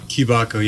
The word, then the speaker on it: Kibako: After that, we're going to play, I guess we'll come back, but how do you pronounce their name? Kibako? – Kibako, yeah. – Okay. – It's Wooden like, Box, Kibako.